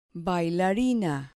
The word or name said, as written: bailarina